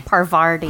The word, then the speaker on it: Parvati: Pavarti. – Other lady. – Parvati. – Parvati. Yeah. – Parvati. Yes.